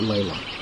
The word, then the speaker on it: Leila: And L-A-L-A? – No, it's L-E-I-L-A. – Leila, but Leila. – Leila is how she pronounces it.